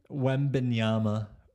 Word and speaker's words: Wembanyama: Wembanyama.